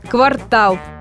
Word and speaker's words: квартал: Сомнения в сторону, современные лингвисты непреклонны. Единственный верный вариант – квартал.